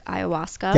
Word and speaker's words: ayahuasca: because I know you did try the drink ayahuasca.